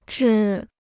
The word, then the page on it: 制: zhì